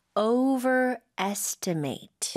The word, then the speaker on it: overestimate: Overestimate. – Overestimate. – Yeah. – 이거는 발음할 때 좀 주의해야 될 것 같습니다. 동사이기 때문에 마지막에 must이 아니라 mate. 하는 게 맞겠죠.